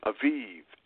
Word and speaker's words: Aviv: Again, which is also in the month of Abib, A-B-I-B. Now, the word Abib, as pronounced by the Israelis and the Jewish people, they pronounce it Aviv, A-V-I-V.